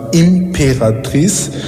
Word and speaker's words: Imperatrice: Wenn man einfach eingibt, Imperatrice, am Ende mit CE, auf Französisch ausgesprochen, MK, also Imperatrice MK, wird man uns finden.